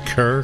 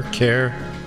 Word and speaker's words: Kerr: from the Anita Kerr Singers. Kerr? Kerr? Don't forget to tell us which pronunciation you prefer in our upcoming listener survey.